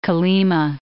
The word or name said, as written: Kolima